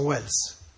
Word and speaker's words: Welles: Orson Welles, l'altro era Wells senza E, questo Orson Welles con la E, però la pronuncia è uguale.